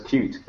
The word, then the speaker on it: Qt: it's pronounced Qt, it's one word.